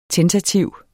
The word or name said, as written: TENTATIV